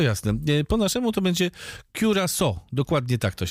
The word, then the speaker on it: Curaçao: Let's start with how you should not pronounce it. Curacao, curaco, curaçao, curacao, cucarachao, curaciao. The correct way to say the name of a beautiful Caribbean island. And the world famous liquor is Curaçao.